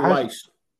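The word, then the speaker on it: Weisz: Is it Rachel Wise or Weiss? – Rachel Weiss.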